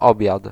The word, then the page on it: lunch: /lʌntʃ/